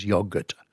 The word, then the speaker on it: yogurt: I've always said yogurt. Until I discovered that in Turkish you don't pronounce the G.